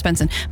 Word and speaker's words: BNXN: But No, it's pronounced Benson, but it's spelt B-N-X-N, and that's what confused me.